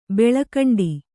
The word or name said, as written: ಬೆಳಿಕೊಂಡೆ